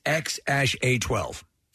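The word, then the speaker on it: X Æ A-12: I finally learned how to pronounce the kid's name. It's X-A-12.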